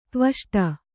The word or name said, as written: ದ್ವಿಷ್ಠ